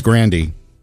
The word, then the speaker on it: Grande: Her family pronounces her name differently. – It's Grandy.